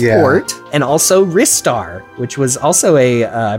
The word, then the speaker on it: Ristar: the official pronunciation, as dictated by one is shot. – Ristar. Like, yeah, yeah, by one shot.